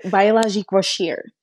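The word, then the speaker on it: Biologique Recherche: It's French, so it's Biologique Rochers. Rochers.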